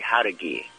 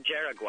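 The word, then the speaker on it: Jauregui: It's pronounced Haudugi. Haudugi. – Haudugi? – Well, Haudugi. There's no D in this name. It's J-A-U-R-E-G-U-I. – I know, it looks like Jaraguai, right? – Yeah, Jaraguai.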